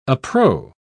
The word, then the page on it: appro: British English